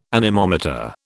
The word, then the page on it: anemometer: British English